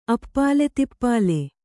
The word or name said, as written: ಅಪ್ಪಾಲೆತಿಪ್ಪಾಲೆ